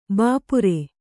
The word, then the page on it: ಭಪ್ಪರೆ: Kannada